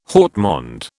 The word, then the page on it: haut monde: British English